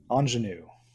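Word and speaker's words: ingénue: The Ingenue, I think I pronounced that right. – Ingenue.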